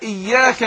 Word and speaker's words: إياك: إزاي أقول إياك؟ إي، للأسف كثير من الناس بينطق الكسرة هنا بيميلها فيقول إيه، يعني ينطقها زي الحرف الإيه الإيه، يقول إيه إياك نع إياك إيه، لا انطقها زي الحرف الإي، أقول إياك.